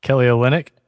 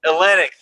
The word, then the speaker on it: Kelly Olynyk: Kelly Olynyk? – Olynyk, thank you.